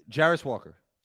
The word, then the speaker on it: Jarace Walker: Jairus Walker.